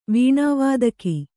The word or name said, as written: ವೀಣಾವಾದಕಿ